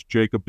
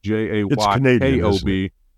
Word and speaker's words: Jaykob: Uh, on LinkedIn, it's Jaykob Dylan Morse. Uh, it's spelled weird. We can we can figure that out. It's J A Y K O B.